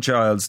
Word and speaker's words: Chiles: It's Simone Biles and Jordan Chiles. – Ah, okay. So these are one of the well-known crew, right? Yeah. Okay, anyway. Jordan Chiles did her floor exercise and she got a score of 13.666, right? – Yeah.